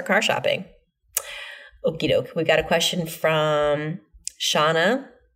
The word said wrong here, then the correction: Shanna: No, Shanna. She says rhymes with banana.